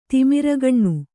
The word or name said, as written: ತಿಮಿರಗಣ್ಣು